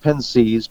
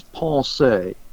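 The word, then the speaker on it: Pensées: It's Pascal's book, which is spelled in English like it would be pronounced Pensees, but actually the French, I'm told by those who know, is pensée.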